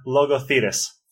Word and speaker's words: Logothetis: Today's guest on the Answers from Leadership podcast is Leon Logothetis.